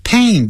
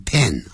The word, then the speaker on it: pain: 哇真是扣人心弦好棒的景色，你看，山就是山，水就是水，哇哦，还有那边唱所谓的这个我们讲的牧歌，这个牧歌是怎么唱的？牧童呢坐在背上就这样，来哟到我们的山中来哟，看到我在放牛哦，你看你的声音真好听的。好好，那这时候呢就是说 I feel a pain, feel a pain, feel 跟 a 产生连音 feel a pain, pain 不要念成 pen,